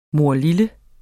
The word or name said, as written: morlille